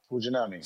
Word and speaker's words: Fujinami: I can't even say his name. For the Athletics. Fujinami. Thank you. – Fujinami.